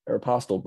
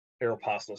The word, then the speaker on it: Aeropostale: They own Prince Sports, Tap Out, Volcom, Nine West, Brooks Brothers, Aeropostale, I don't know how you say it, and Izod. – Aeropostale, bro. – Aeropostale, sorry.